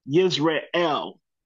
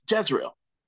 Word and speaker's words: Jezreel: It's actually pronounced Yisrael. Yisrael. But we call it in English Jezreel.